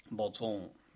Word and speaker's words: Batzer: Skrives eh, B-A-T-Z-E-R. Trolig kult navn. Kan man også uttales uh, Batzer. Botson. Vilde Botson.